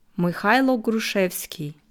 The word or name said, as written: Mychajlo Hruschwesky